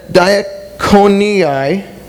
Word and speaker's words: diaconii: the diaconii, that's how you pronounce that word, of Constantinople.